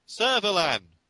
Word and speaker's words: Servalan: Servalan. There we go. – Hello, Supreme Commander Servalan. So what did you... Then we're going to, like, cut. Look, it's pronounced Servalan.